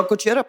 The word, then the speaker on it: Gojira: Wir gehen ein bisschen nach Frankreich rüber, zu Gujira, so würde ich sie aussprechen, oder Claudia, unsere Japan-Expertin? Ja, unbedingt. ja, Gujira passt schon. – Na, weil passt Französisch schon.